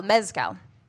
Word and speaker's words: mezcal: When you say mezcal, am I saying it wrong when I'm like, I'd love, can I get a spicy margarita with mezcal? Am I saying it wrong? No, that's mezcal.